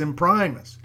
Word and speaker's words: Imprimis: So I clicked on that, and the little video started off kind of making fun of how people pronounce Imprimis, and they don't know how to say Latin words, so a lot of people say it wrong. Then they have a Hillsdale College professor of Latin who comes on and tells you how to say it. He says it's Imprimis.